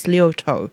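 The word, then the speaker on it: Liautaud: I think the last time I played her take on Daniel Lanois' Jolie Louise, I'd forgotten how to pronounce her surname, because it's L-I-A-U-T-A-U-D. But I've got a feeling I read somewhere that it's pronounced Tess Liotto, who often plays with Jess Shanks of the Eastern.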